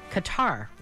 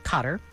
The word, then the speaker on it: Qatar: And when he was very, very young, Sean left for Qatar, or Cotter, depending on how you say it,